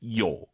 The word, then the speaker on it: 有: 사실은 근데 일상생활에서는 요 올라가면 안 돼요. 뒤에가 일성이니까 반삼성이에요. 요, 요 이렇게 발음하는 거예요. 다시 들어보세요. – 저附近有超市吗? – 이렇게 가는 거예요. 요 올리면 안 돼요.